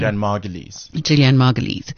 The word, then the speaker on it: Julianne Margulies: Julianne Margulies.